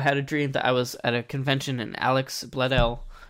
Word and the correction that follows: Alexis Bledel: Did I say her name right? – Alexis Bledel. – Yes, yeah. Got it.